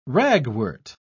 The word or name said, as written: ragwort